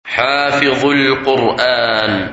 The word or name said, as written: Hafith Al Quran